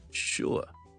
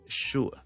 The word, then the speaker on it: sure: 老一辈啲嘅人咧，好多时候咧有啲会将佢读成咧系 sure，亦都得 sure sure sure 都得。似乎你想懒啲咧嘅英国人咧就 sure 就得啦，OK 未必需要 sure。